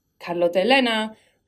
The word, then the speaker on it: Carlotta Elena: and I kind of need to start introducing myself as Carlotta Elena again, because that's who I am. And if you can't say it like that, that's fine, but yeah. I shouldn't be saying it incorrectly because I know people are going to repeat it incorrectly back to me.